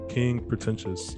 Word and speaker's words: King Pretentious: KP stands for King Pretentious.